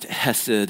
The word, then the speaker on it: hesed: That word steadfast love that you see there, it comes from this Hebrew word. It's pronounced hesed, and sometimes it's translated as mercies.